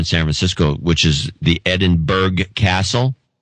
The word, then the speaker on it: Edinburgh: That came in from Edinburgh, Scotland, properly pronounced Edinburgh. So we had a big discussion, the family discussion, about this. How does E-D-I-N-B-U-R-G-H get to be pronounced Edinburgh? – Edinburgh. – It's pronounced, but it's Edinburgh is what they say.